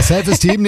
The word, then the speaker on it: Self-Esteem: The Offspring. Self-Eastern. – Ja, die Aussprache ist ein bisschen... – Steam ist Steam. – Ja. Ja, ja, ist richtig. – Ja, Self-Esteem nehmen wir auch.